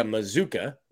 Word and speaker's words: Mazuka: But I did look up Micah Mazuka, rhymes with bazooka, from the Baylor website.